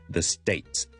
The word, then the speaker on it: States: 发音上咧系的确系有一个地方咧系需要我哋好留意嘅，就系、是、咧 S-T-A-T-E-S 咧后面个 T-E-S 咧系一个 T-S 嘅发音嚟嘅，State 个 T 跟住楞埋个 S，我唔可以就咁读成咧 the States 唔得，唔系 States。 stay 完之后呢我要有个 T 的条例上返去 TM state，即是正常我哋 state 嗰个位嗰、那个 TM 嗰个位。